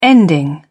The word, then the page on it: ending: British English